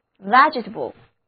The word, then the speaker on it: vegetable: 这里看看它的发音，第一个字母 e 要读作 e，第二个字母 e 读作短的 e， vegetable。